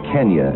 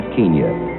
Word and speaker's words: Kenya: Take the telephone poles in Kenya, and that's how it's pronounced, by the way, Kenya, not Kenya.